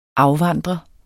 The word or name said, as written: afvandre